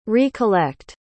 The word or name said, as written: re-collect